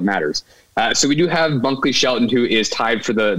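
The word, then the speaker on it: Bunkley-Shelton: Is it Bunker Shelton? Is that what it is? – Bunkley Shelton.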